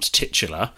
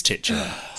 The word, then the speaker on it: titular: Last week, when Ben pulled me up on my pronunciation of titular, I very adamantly said, it's not titular. And again, having consulted my phonetic and BBC Oxford English pronunciation guides, both of them, I'm wrong. It is titular. I think it's just that I try to avoid tits and have spent 30 years trying to do that. I've got rid of any tit in my pronunciation.